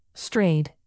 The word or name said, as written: strayed